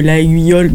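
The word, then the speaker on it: Laguiole: Oui, alors ça s'écrit Laïole, mais c'est Laïole. Oui, comme les couteaux.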